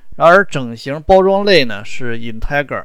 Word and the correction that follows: integer: 啊，这个其实我一直发音不太对啊，这应该是 integer 啊。